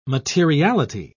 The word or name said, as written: materiality